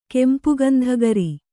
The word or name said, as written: ಕೆಂಪುಗಂಧಗರಿ